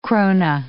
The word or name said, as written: krona